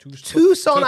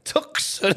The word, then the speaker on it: Tucson: Is how you pronounce it? – Tucson, Arizona. Tucson. – Tucson.